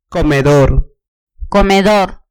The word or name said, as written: comedor